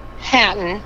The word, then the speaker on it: Hatton: Hatton.